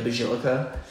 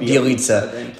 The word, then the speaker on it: Bjelica: It's Nemanja Bjelica. But I always say, Bjelica, fuck yeah, like the Team America song. – America? Bjelica? – Yeah. – I don't know how you get that from that. – Bjelica.